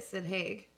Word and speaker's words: Sid Haig: I believe that's how you say it, Sid Haig.